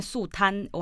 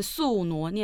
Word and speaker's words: 宿儺: 我确定这个发音是对的，因为我刚才在进录音室的时候，我们要录音的时候要取一个档名嘛，我就打了两面宿儺。没错，这个发音是对的，就出现这个字了。 – 没错，所以不要再讲什么两面宿贪，我还听到有人讲两面宿男，简就就是放弃去思考那个字怎么念，你知道吗？OK，所以下次有人再跟你说说，你就可以纠正他了哈。